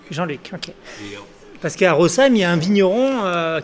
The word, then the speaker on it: Rosheim: Alors on va dire un truc pour tous les Français qui nous écoutent, le SH dans les noms de villages alsaciens se prononce « se » et non pas « che ». Donc c'est Rosheim, hein, le très beau village de Rosheim.